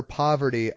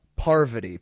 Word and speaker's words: Parvati: He says it, he literally was calling her Poverty up through the early days of her third season. – So wait, how do you say it? – Parvati, Parvi.